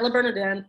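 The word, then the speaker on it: Le Bernardin: I was going to call it La Bernardini, but I have to say it correctly the first time, La Bernardin.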